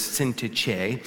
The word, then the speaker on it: Syntyche: And in this particular situation, there's a couple of ladies, Euodia and Syntyche, is one way to pronounce it. Another word is Syntyche, who apparently were fussing with one another.